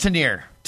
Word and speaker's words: Tanier: Yeah, I think it's Tanier. – Tanier. I'm sorry. Tanier. It's spelled one way and then it's pronounced another way.